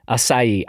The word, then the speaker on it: acai: Acai. I'm going to go with society as a potential mnemonic and see how that rolls.